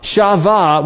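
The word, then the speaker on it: sava: Shava, which is S-A-B-E-A, is just us trying to, you know, write it down. But shava is how it's pronounced.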